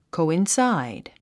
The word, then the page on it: coincide: kòuinsáid; コゥインサァイド